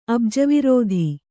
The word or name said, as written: ಅಬ್ಜವಿರೋಧಿ